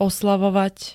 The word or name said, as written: oslavovať